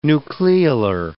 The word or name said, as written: nucleolar